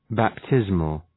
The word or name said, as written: baptismal